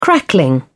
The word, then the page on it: crackling: British English